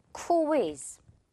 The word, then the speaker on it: quiz: 근데 퀴로 바로 가지 말고 우리 지금 이 Q, U 한 세트 보이죠? 이 부분, Q 부분을 살려서 이렇게 발음을 하는 거예요. Quiz. 그냥 퀴 바로 가는 것보다 지금 조금 더 느리게 코이 이렇게 나눠 가야 정말 자연스러운 영어 발음이 된다는 거.